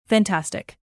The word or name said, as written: fantastic